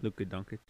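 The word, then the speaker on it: Luka Dončić: So I didn't want to pick him up for that reason, and I found Dodonic, or I don't remember how to say his name. You know who I'm talking about. – Luka Doncic.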